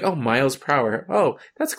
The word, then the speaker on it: Prower: Prower? – Yeah. How do you say it? – Prower.